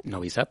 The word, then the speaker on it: Novi Sad: Novi Sad. – Novi Sad, Novi Sad una, es una ciudad resurgida de sus cenizas tras los bombardeos de la OTAN en la guerra de Kosovo.